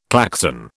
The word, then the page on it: Klaxon: British English